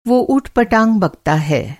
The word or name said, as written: ऊट-पटाँग